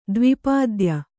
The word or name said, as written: ದ್ವಿಪಾದ್ಯ